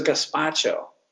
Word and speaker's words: gazpacho: There was a gazpacho, like a, I may not even be saying that right, but it's a.